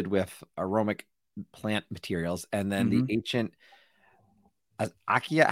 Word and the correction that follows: acacia: How did we say this one now? – Acacia. – Acacia, that's it.